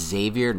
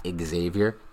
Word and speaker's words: Xavier: and that's it's Xavier, not Xavier. The X is not pronounced in his name.